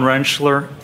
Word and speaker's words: Rentschler: Dr. Andrew John Rentschler, A-N-D-R-E-W-J-O-H-N-R-E-N-T-S-C-H-L-E-R.